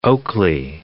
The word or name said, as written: Oakley